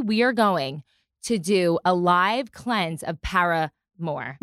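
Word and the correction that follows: ParaGard: No, Paraguard. Okay, I've been teaching her how to say this word. It's Paraguard. I mean, maybe I'm saying it wrong, but it's Paraguard.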